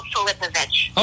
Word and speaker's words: Filipovic: It's the top one, Jill Filipovic.